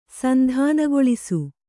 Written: ಸಂಧಾನಗೊಳಿಸು

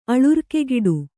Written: ಅಳುರ್ಕೆಗಿಡು

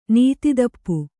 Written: ನೀತಿದಪ್ಪು

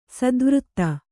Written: ಸ್ಥಾವರತೆ

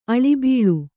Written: ಅಳಿಬಾಳು

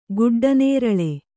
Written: ಗುಡ್ಡನೇರಳೆ

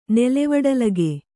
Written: ನೆಲೆವಡಲಗೆ

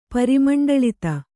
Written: ಪರಿಮಂಡಳಿತ

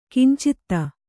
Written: ಕಿಂಚಿತ

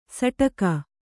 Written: ಸೂಡಿಗೆ

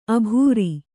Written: ಅಬರು

